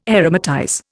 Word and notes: aromatize: Indian

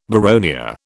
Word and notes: boronia: British English

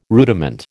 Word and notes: rudiment: American English

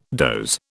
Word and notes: doze: British English

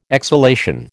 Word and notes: exhalation: American English

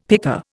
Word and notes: picker: Indian

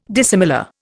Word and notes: dissimilar: Indian

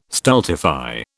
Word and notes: stultify: British English

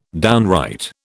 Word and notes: downright: British English

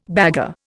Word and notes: bagarre: Indian

